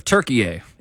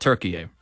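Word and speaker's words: Türkiye: Is in today for the country of Turkey-A, and he does want you to call it Turkey-A.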